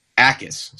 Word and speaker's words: Jacas: His last name is spelled J-A-C-A-S, but it's pronounced Akis. So silent J there.